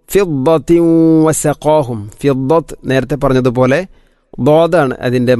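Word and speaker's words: വസഖാഹും (وسقاهم): വസഖാഹും വസഖാഹും, ഖാഫിനെ ഉച്ചരിക്കുമ്പോൾ വസഖാ ഖാ അല്പം കനപ്പിച്ച് ഉച്ചരിക്കണം. വസഖാഹും, അല്ലാതെ വസഖാഹും അല്ലെങ്കിലോ വസഖാഹും എന്നോ അല്ല ഉച്ചരിക്കേണ്ടത്. വസഖാഹും.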